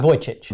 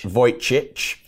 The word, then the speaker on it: Vujicic: Um, there's this amazing speaker that's called Nick Vujicic. – Yeah. – I may have mispronounced that. – Vojcic. – Vojcic. Um, yes, I've butchered that.